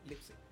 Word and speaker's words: Leipzig: No Bueno, nos vamos a la parte internacional, ya como más grande, y tenemos el listado completo para la DreamHack Leipzig 2020 se, pronuncia no, lipsic lipsic Bueno, tenemos a Virtus Pro, que es uno de los invitados, junto a Nord, Renegades y Vic, que serían los invitados completos.